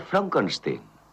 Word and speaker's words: Frankenstein: Frankenstein. ¿Me toma el pelo? No, se pronuncia Frankenstein. ¿Dice usted también Froderick? No.